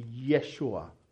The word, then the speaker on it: Yeshua: In correct pronunciation of Hebrew word, it will be Yeshua. And Yeshus will be the right, correct way of saying in Greek, which means Joshua means God saves.